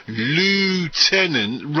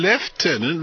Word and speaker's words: lieutenant: From Mike in Leeds by text, why do Americans say lieutenant rather than lieutenant like us Brits? – Go ahead, Grant.